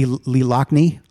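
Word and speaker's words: Loughnane: So we get to hear the amazing horns of Lee Lughane. Is that how you say his name? – Lee Lochney. – Lee Lochney. – Lochney. – Lochney. – I think it's Lochney.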